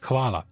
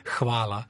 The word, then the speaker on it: Hvala: Hvala. Eh, era troppo difficile, non lo dico. Perché comincia con una H, eh. bisogna ah. pronunciarla Hvala.